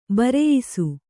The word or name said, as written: ಭರಾಯಿಸು